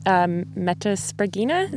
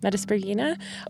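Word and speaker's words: Metaspriggina: Metaspragina, is that how you say it? – Yep. – Metaspragina.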